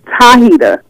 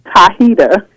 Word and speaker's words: Tahita: Tahita with a T. – All right, Hi-Hita. All right, baby. – Tahita.